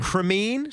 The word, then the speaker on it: Cremean: Uh, Matt, how would you say that? Cremine? Sounds about right. Cremine, C R E M E A N.